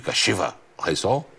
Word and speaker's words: Kashiwa Reysol: Ficou boa essa pronúncia de Kashiwa Reysol? É assim, né, Lamércio? – Ficou, temos ir, temos ir.